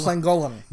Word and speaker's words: Llangollen: Is Llangollen? – Llangollen.